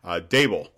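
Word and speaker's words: Daboll: Coordinator, let's see, Buffalo Bills, Bedall. I'm saying his name wrong. I'm sorry, Dable, Brian Dable, excellent offensive coordinator.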